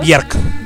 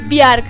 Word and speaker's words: Björk: Björk. – Björk.